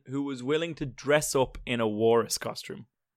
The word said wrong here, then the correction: walrus: How are you pronouncing that word there? – Warris? – Walrus? Oh, that's interesting. – Do you say it's walrus? – Walrus. Yeah, it's not that I say walrus. That's how it's said.